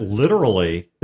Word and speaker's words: literally: So the other thing is I want you guys to know that literally is three syllables, and battery is also three syllables.